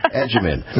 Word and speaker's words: Agyeman: Agyeman. Phonetically figured that out like we would use in Italian, agita.